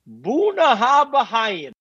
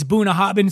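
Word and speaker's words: Bunnahabhain: Like Boona Habahain. – It's B-U-N-N-A-H-A-B-H-A-I-N. It's Boona Hobbin.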